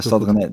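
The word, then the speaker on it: Stade Rennais: Dat zeg ik het goed, toch? Of niet? Stad René, ja. Ja, Stad René.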